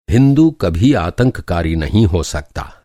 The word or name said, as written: आतंककारी